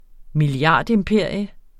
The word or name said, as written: milliardimperium